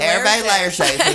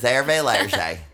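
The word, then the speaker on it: Hervé Léger: Hervé Lerche. His name is Hervé Lerche.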